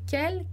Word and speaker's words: quel: E aí não vai ter diferença de pronúncia em relação ao feminino, ao plural, vai ser sempre quel, quel, quel e quel.